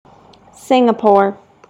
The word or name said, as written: singapore